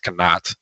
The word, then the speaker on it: Knott: Like I was with Oliver Knott, and that's how you pronounce it, by the way. It's not, it's Knott. It's the German pronunciation.